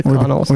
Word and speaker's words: Connells: How would you say this band name? The Connells? – I was going to say, yeah, the Connells. – Or the Connells. – I would say Connells over Connells.